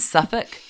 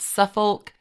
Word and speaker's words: Suffolk: It's Suffolk, not Suffolk.